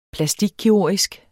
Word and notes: plastikkirurgisk: plaˈsdig-; ˈplasdig-

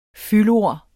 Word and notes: fyldord: [ ˈfyl- ]

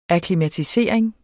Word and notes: akklimatisering: aklimatiˈseˀɐ̯eŋ; ɑkli-

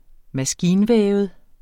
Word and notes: maskinvævet: -ˌvεːvəð; -ˌvεˀvəð

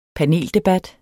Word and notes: paneldebat: dansk

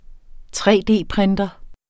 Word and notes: 3D-printer: tre-d-printer

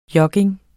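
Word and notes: jogging: Danish

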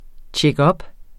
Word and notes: checkup: tjεgˈʌb

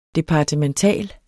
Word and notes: departemental: [ ˌdepɑdəmənˈtæˀl ]

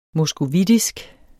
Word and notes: moskovitisk: mʌsgoˈvidisg; mos-

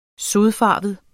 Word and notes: sodfarvet: [ ˈsoðˌfɑˀvəð ]; [ -ˌfɑːvəð ]

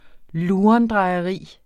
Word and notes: lurendrejeri: ˈluːʌndʁɑjʌˌʁiˀ; ˈluˀʌn-

